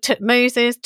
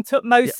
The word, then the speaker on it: Thutmose: I think people worry far too much about pronunciation in the past. Phew. We don't have tape recorders, unfortunately, and you can read ancient inscriptions in a lot of different ways. So I call him Thutmose.